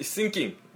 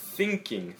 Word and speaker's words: thinking: 뭐 Titanic 호에 대해서 얘기할 때 it's just sinking, sinking. 그래서 그 다른 의미 가지고 있으니까 S나 뭐 시옷, 상시옷 그 발음으로 하지 마시고요. 정말 thinking, thinking 이라고 하셔야 돼요.